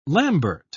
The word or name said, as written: lambert